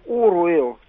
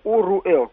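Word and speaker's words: Оруэлл: И второе, все-таки мне кажется, что в русской транскрипции, по крайней мере, слово «оруэл» читается как «оруэл», так же как «w-a», «w-i», «у-и», «у-э», «у-о», «уотсон», а не «ватсон», «уильямс», а не «вильямс», ну и так далее. Это, так сказать, моя личная точка зрения, естественно, я никому ее не навязываю. Но я стараюсь писать именно так, например, допустим, в материалах, и произносить то же самое. Поэтому для меня он «оруэл», извините, конечно.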